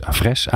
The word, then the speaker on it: Avres: Sibren Houtman van ELO en Oebele Bruinsma van Avres is... Avres? Avres? Avres. Ja, ik doe een rare klemtoon.